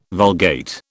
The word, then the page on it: Vulgate: British English